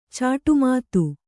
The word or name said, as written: ಚಾಟುಮಾತು